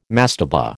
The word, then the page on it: mastaba: American English